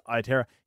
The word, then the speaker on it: Aotearoa: I hope I've pronounced that right. Reflex, you can tell us if we haven't. But I'm sure it's Aotearoa.